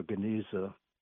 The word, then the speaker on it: Geniza: Yeah, it's pronunciation would be more Geniza.